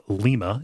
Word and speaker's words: Lima: we have a town called Lima. It's spelled L-I-M-A, but we say it like lima beans.